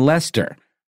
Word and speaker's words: Leicester: based in Leicester, not Leicester, like you think it would be pronounced when you look at it on paper. – It's a tricky one. – It's a really but it's like Worcester in Massachusetts.